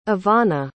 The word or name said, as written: evanna